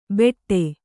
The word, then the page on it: ಬೆಟ್ಟೆ: Kannada